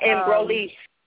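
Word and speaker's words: Embryolisse: My moisturizer I may be pronouncing it wrong, I always pronounce it by Embrulese. It's French.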